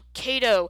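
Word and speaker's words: CATO: It's C-A-T-O, Kato.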